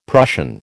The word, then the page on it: Prussian: American English